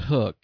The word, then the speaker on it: Huk: I don't know how to really truly pronounce it. H-U-K? – I believe it's Hook. – Probably Hook.